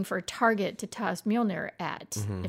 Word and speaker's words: Mjolnir: It's a weird, like, the accenting on that Mjolnir.